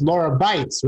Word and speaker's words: Lara Beitz: It's Lara Beitz, right? – Yeah, Lara Beitz.